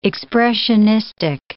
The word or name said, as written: expressionistic